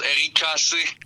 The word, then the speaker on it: Rixé: Ben lui, il prononce Eric Cassé. – OK, carrément, lettre par lettre, Eric Cassé. – Ouais, exact. Dans ses tracks, c'est Eric Cassé. Beaucoup de Québécois l'appelaient le Rixé.